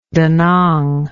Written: danang

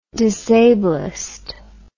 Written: disablist

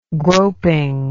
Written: groping

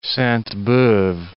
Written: Sainte-Beuve

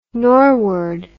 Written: norward

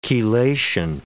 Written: chelation

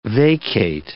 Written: vacate

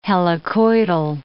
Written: helicoidal

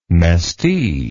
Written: mestee

